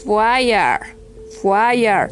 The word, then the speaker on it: wire: Se escribe W-I-R-E, pero se debe de pronunciar fire, fire, fire, fire. Es muda, como en la palabra amigo, que se escribe F-R-I-E-N-D, pero no se va a, esa e, y no va a sonar, esa A y no va a sonar.